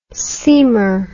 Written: seamer

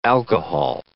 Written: alcohol

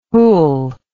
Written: wholl

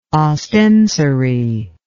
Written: ostensory

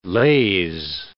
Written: laze

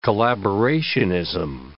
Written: collaborationism

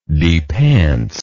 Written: depants

